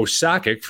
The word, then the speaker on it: Sakic: Or Joe Sakic for the hockey world, right? I mean, that's not right. pronounced properly, not by where their country, you know, it's it's not a hard C, right?